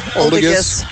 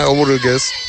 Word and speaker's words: Oldigas: So I think I've been saying that name wrong my whole life. – Oldigas. – I just thought it was a soft G. Learn something new every day.